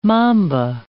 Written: mamba

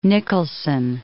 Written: Nicholson

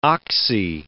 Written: oxy

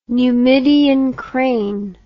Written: Numidian crane

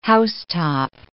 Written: housetop

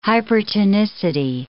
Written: hypertonicity

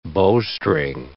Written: bowstring

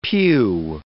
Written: pew

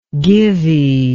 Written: givey

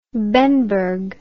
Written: bemberg

